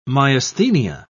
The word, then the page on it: myasthenia: British English